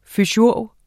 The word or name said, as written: fysiurg